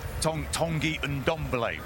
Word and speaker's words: Ndombele: Tanguy Ndombele, we believe, is the pronunciation.